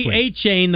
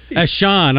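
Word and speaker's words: Achane: A-Chain, the football player. – Yeah, right. – As Sean. I'm like, no, it's A-Chain.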